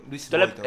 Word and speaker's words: Louis Vuitton: oye, tiene que ser una marca Luis Vuitton. – Louis Vuitton.